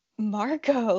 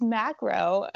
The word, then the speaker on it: macro: And he was like, "Marco lens." And I was like, "Yeah, the lens, the zoomed in lens." He's like, "It's macro." And I was like, "Oh my God." I was like, "I've been saying it wrong for years and no one corrected me."